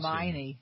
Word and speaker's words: Hermione: Apparently kisses Hermione during a fantasy sequence. – Hermione. – Hermione? Too. – Yeah. – Hermione. – Would I call her Hermione? – Yes.